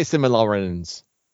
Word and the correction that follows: Silmarillion: I have no idea if I'm saying it right. – Silmarillion.